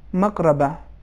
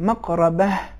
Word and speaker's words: مقربہ: ان موارد میں حرف ہا سنائی نہیں دیا، آپ کے تلفظ اس طریقے سے تھا مقربہ، لیکن صحیح تلفظ یہ ہے مقربہ، آخر میں ہا یہ سنائی دینا چاہیے.